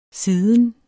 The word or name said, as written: siden